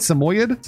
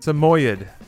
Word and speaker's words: Samoyed: a combat Samoyed. Is that how you say it? Samoyed? – Samoyed. That's what Google says.